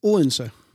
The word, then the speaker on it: Odense: And you say, how do you say Odense in, when you're Danish? – Odense. – Yeah. It's a really difficult word to pronounce. – It is. It's very flat.